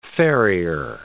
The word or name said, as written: farrier